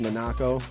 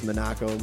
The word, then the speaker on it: Monaco: But my parents told me it's Monaco. – Monaco, okay. – From Italy, it was always Monaco. They spelt it differently, but now it's Monaco, Monaco, whatever you want.